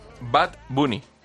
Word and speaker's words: Bad Bunny: Bad Bunny, ¿bien? – Sí, sí.